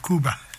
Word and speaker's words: Cuba: And before that was Cuba, or Cuba, as they pronounce it.